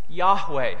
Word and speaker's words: Yahweh: And God's name is often pronounced as Yahweh.